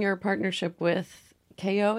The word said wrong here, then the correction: Kyo: is that how you say it? – Kyo.